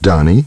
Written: Donnie

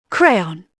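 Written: crayon

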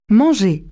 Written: eat

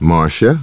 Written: Marcia

